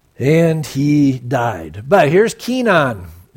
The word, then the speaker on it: Kenan: It says, Enosh lived 90 years and begot, this is pronounced Kenan.